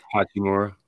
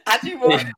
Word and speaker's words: Hachimura: So LeBron came out hot, started hot, and Uchi, what's his name? Uchi, how do I pronounce it before I say it wrong? – Hachimura. – Hachi, so Uchi. Uchiwali. No, Hachimura.